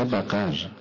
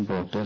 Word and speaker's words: باقر: بقر استجیر بالله. حضرت عصبانی نشدن، فهمدن اشتباه تلفظ کردید، من باقر هستم یعنی شکافنده علوم.